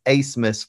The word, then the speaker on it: Abmas: The A-B-M-A-S is pronounced ace, as in what you do in maybe a tennis match or a volleyball match. So it's Asmus for all our listeners out there.